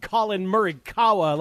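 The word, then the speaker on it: Collin Morikawa: You know, we can't all just toggle to golf and root on Colin Murikawa like you did on Sunday.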